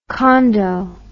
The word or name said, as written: Kondo